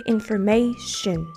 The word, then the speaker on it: information: 最後のこの「 O」の音は、まあ、発音ほぼしません information 旬旬はいえー、